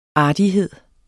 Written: ARTIGHEDENS

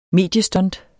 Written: mediestunt

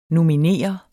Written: nominere

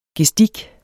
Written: gestik

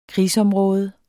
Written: krigsområde

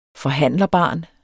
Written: forhandlerbarn

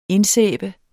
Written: indsæbe

